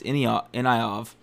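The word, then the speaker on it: Ivanov: Then he fought Blagoy Ignyov. Inayov. I've always said that wrong. Apparently, it's Inayov. There's no G. I always say G for some reason.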